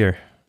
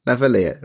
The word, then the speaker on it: lavalier: Well, how do I pronounce that word properly? – Lavalier. – No idea. Lavalier? – Lavalier. – Lavalier. Right. Thank you.